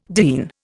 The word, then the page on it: diene: Indian